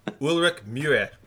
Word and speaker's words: Ulrich Mühe: Ulrich Mühe.